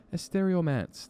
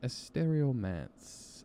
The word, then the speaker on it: Estereomance: Really cool kind of crossover pop, rock, Spanish, English, awesome band uh, called Estereo Romance. Oh, am I pronouncing that right? Estereomance. That's what it is. Estereomance and they got a new song.